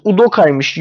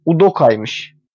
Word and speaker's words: Udoka: Bu arada Toru telaffuz Udoka'ymış. Udoka değil. Udoka'ymış. Nijeryalı olduğu için.